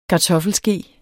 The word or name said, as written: kartoffelske